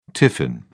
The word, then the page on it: tiffin: British English